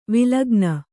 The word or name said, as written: ವಲ್ಗನೆ